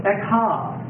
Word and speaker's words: Ekhah: The Hebrew title is Echa. Taken from the first word. That's spelled E-K-H-A-H.